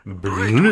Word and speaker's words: Brut: Brut is a typical French pronunciation.